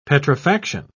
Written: petrifaction

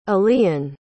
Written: allean

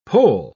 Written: poll

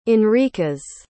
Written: enriquez

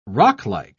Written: rocklike

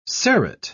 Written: serrate

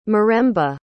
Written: miremba

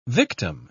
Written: victim

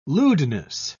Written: lewdness

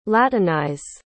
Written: latinize

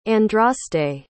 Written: andraste

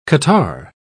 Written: catarrh

